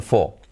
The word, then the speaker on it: full: No se dice full, sino fo.